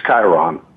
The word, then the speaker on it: Chiron: Now, is it pronounced Chiron or Chiron? It's Chiron. Chiron. We've been calling it Chiron.